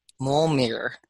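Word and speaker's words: Mjölnir: And so, Jessica, what are your thoughts on the property rights associated with Mjolnir, Thor's hammer? – Ooh, good job pronouncing it.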